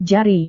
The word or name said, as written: jari